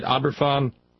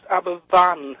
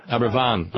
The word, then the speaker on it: Aberfan: It's actually pronounced Aberfan. – Aberfan, okay. – We've got a slightly different alphabet in Wales. If you've got a double F, that's pronounced as an F. If you've got a single F, it's pronounced as a V.